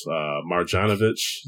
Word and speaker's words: Marjanovic: It was Marjanovic. That's how you say his name.